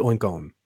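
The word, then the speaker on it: Oinkologne: Oikone? It's like clone, Oikone? – Yeah, Oinklone. – Oh, Oikone. Yeah. It's probably Oinkone. – Okay. – If it's like cologne.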